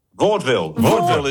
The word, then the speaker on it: Vaudeville: I've never been sure if it's Vaudeville or Vaudeville. – Vaudeville is the correct pronunciation.